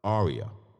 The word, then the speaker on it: ARIAH: Am I saying that right, bro? ARIAH? – ARIAH. – Okay, ARIAH Foundation.